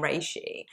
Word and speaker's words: Reishi: But, um, no, is it Rishi, Roshi? How do you? Yeah, Rishi. Yeah, I've heard it pronounced Rishi and Reishi. Yeah. Um, I tend to say Reishi, but I don't think it matters.